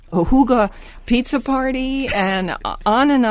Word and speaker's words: hygge: And don't forget, people, it's spelled, The Higgy Life is the name of the book, and it's spelled H Y G G E, but you pronounce it um, in the language Huga you know, H O O G U H, Huga, like that.